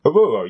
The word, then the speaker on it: Oruro: And one of the places that really interests me was a place called Oruro. Is that the correct pronunciation? – Oruro, yes. Yes, it is.